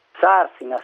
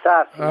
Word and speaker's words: Sarsina: Sarsina o Sarsina? – Sarsina, Sarsina. – Allora ho detto bene.